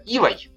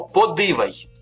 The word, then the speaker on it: ивой: Например, ивой ивой, такое дерево, да? Ивой. Но под ивой. Здесь э, слово одно и то же, но под ивой в слитной речи произношение меняется. На фонема гласная «и» меняется на фонему и.